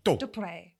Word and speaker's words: Daubreuil: Dubreuil. Just for listeners, it is spelt D-A-U-B-R-E-U-I-L.